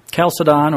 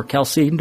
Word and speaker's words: Chalcedon: So when you have the Council of Chalcedon, or Chalcedon, depending on who's pronouncing it, in 451, they defined the hypostatic union.